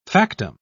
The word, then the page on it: factum: British English